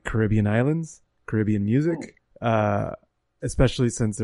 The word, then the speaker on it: Caribbean: Uh, by the way, uh, so Carib- Caribbean comes from the native indigenous tribe called the Caribs, and they pronounce oh. it Carib. – And so, because the pronounce the, the emphasis on the first syllable, it ends up when you use the whole word, it's car- the whole word, it's Caribbean.